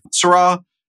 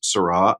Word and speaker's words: Sirui: Syrah, they are spelled S-I-R-U-I, but it's pronounced Syrah, apparently.